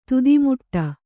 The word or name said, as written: ತುದಿಮುಟ್ಟ